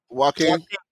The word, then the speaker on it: Joaquin: Jonah Phoenix. I definitely butchered his name. – Joaquin.